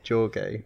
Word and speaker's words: Jorge: Jorge, or Jorge, is it Jorge? Because he's Portuguese. They like to pronounce their J's, don't they? – Jorge.